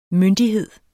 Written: MYNDIGHEDERNES

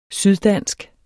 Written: syddansk

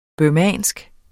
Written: burmesisk

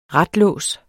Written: RATS

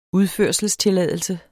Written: udførselstilladelse

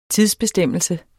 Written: TIDSBESTEMMELSE

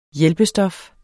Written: hjælpestof

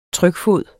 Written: trykfod

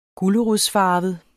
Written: gulerodsfarvet

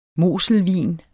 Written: moselvin

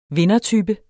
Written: vindertype

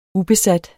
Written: ubesat

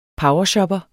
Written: powershopper

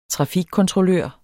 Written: trafikkontrollør

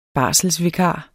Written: barselsvikar

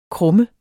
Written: krumme

